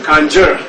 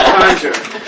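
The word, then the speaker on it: Kangyur: The first Western people to ever come across these books came across them in East Tibet, and the Eastern Tibetans called them Ganjur. Like, hey, y'all doing? This is Buddha's speech. And so for all these years, Western scholars have mispronounced this word, Conjure. So if you see it spelled Conjure, you know why, okay? It's Conjure, okay? Ga means the word, okay? Gyur means translated.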